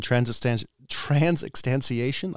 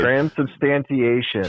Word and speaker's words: transubstantiation: So that's the old guy's opinion on transubstantiation. I really butchered that. – Oh boy, you sure did. – Transubstantiation.